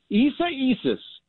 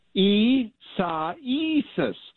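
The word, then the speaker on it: Isaías: Isa Isis. Isa Isis. It is the Spanish for Isaiah.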